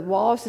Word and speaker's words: Wallis: And so Wallace is spelled W-A-L-L-I-S, because some of you aren't going to believe me, and you're going to look this up.